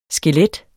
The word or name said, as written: skelet